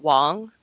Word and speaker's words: Wang: Yeah, he's talking about it, and it's actually, his name's Garrett Wong. – Yes, that is correct. – Oh, sorry. – Spelled Wang, pronounced Wong.